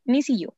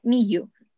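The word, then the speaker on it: NiziU: Y de ahí salió el grupo Nisiyu, que en realidad se pronuncia Niyu, pero lo leemos en español, Nisiyu.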